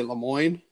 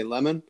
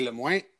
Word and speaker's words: Lemoyne: So it's I say Lemoyne, and everybody else does. If I'm Lemoyne. – lucky, sometimes they say Lemon, but it's supposed to be Lemoyne, but I would never say it like that because it sounds like a bit of a duck.